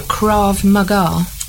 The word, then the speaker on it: Krav Maga: I don't remember you saying Becky is an instructor in Commando Krav Maga.